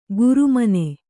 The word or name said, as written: ಗುರುಮನೆ